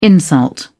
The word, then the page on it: insult: British English